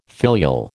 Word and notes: filial: American English

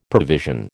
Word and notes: provision: American English

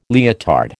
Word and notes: leotard: American English

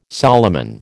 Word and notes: Solomon: American English

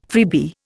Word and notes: freebie: Indian

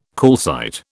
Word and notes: calcite: British English

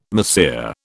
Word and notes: mahseer: British English

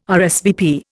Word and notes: RSVP: Indian

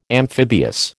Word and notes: amphibious: American English

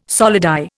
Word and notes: solidi: Indian

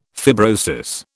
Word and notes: fibrosis: British English

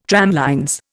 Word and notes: tramlines: Indian

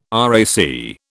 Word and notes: RAC: British English

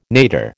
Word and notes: nadir: American English

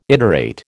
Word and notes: iterate: American English

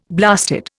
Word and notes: blasted: Indian

